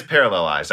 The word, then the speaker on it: parallelized: For a game are a paralyzed workload, or parallelized workload. And it's just quicker to say paralyzed, guys. That's why sometimes I think I do. But it is parallelized. I know how to say it.